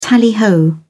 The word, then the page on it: tally ho: British English